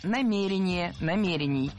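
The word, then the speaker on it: намерение: Сказали, что у нас нет никаких намерений предыдущего руководства. – Намерения – это неверно. Правильно – намерений. Ударение в этом слове ставим на второй слог и не меняем это ударение при изменении слова.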